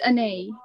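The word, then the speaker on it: Anaïs: It's pronounced Anais.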